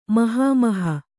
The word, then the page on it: ಮಹಾಮೋಹ: mahāmōha